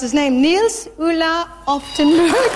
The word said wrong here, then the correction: Oftebro: And the winner was Nils Ulla Oftebro.